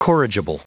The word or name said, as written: corrigible